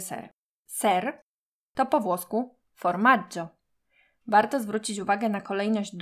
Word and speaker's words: formaggio: W słowie formaggio pomiędzy literkami G i I nie ma literki H. Dlatego w tym przypadku G wymawiamy miękko.